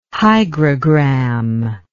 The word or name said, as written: hygrogram